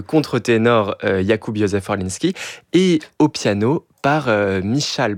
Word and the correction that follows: Michał: Biel, ou plutôt Mikaou Biel, si on veut vraiment bien le prononcer.